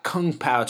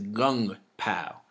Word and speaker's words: Kung Pao: Yeah, once you get over there, just like if you're eating Chinese food, it's not Kung Pao chicken, it's Gung Pao. You pronounce the K's with the G.